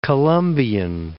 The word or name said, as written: Columbian